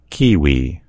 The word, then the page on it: kiwi: American English